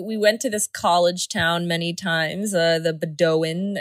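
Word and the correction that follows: Bowdoin: or if that's how you say it. – Bodwin. – Yes, Bodwin.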